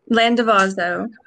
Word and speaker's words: Landavazo: Landavazo.